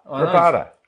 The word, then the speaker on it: Rabada: Is it Rabada? – Rabada. – Well, just say Rabada.